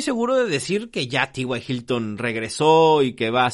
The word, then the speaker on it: T.Y. Hilton: Otro receptor que hay que tener en la mira es a, a T.Y. Hilton. Ya le iba a decir Ty Hilton, pero no era Ty Johnson y T.Y. Hilton. se escribe igual, pero se pronuncia diferente.